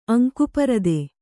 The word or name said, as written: ಅಂಕುಪರದೆ